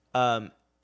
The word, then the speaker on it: Giacchino: How do you pronounce Giacchino? – It? Giacchino. It's just gonna be like those falling violins when you walk around.